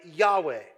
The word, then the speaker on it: Yahweh: And we found out it is actually one word in Hebrew, the word YHWH, which is the divine name of God, which if pronounced sounds like Yahweh.